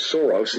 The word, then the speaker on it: Soros: The first person that broke that story on Kushner and in his involvement with George Soros, and yes, I said that correctly, with a $256 million loan with a huge development that they put together, Cadre.